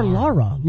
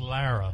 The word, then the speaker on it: Lara: Yeah, but he called her Laura. Like Laura. Like with the heavy... – Who cares? – The dad does. It's weird. I didn't think like that. But anyway... – It's technically pronounced Lara. – You're right. It's Lara.